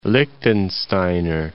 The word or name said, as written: Liechtensteiner